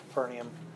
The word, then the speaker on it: Capernaum: There's two ways to pronounce that, but I'll pronounce it Capernaum.